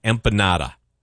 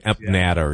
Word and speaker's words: empanada: He says it's called creme fraiche and then empanada. For some reason, I kept saying empanada or something like that.